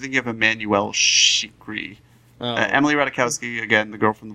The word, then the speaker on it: Ratajkowski: How do you say her last name? – Ratajkowski. – I thought it was Treaky.